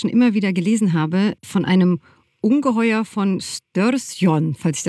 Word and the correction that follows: Storsjön: Und der See heißt Storsjön, das heißt übersetzt großer See.